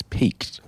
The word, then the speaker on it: piqued: I've certainly, my curiosity has been piqued, and that's P-I-Q-U-E-D. Is that how they say it? I don't know. I think so. Someone look that up. It's not peaked. It's peaked.